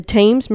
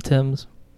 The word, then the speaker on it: Thames: It's called Sherlock Holmes Consulting Detective, The Thames Murders and Other Cases. And it's like... – it's pronounced the Thames. – Is it? – The river, yeah.